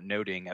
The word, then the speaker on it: Nothing: Much Ado About Nothing is actually pronounced Much Ado About Noting, as I learned long ago.